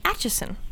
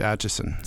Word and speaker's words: Atchison: Apparently, I'm pronouncing that like an Australian. It's Atchison. Atchison? Is that right? – That is correct. Atchison.